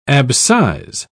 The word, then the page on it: abscise: British English